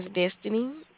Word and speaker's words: Destiny: My name is Destiny. – How do you spell it? – D-E-S-T-I-N-Y.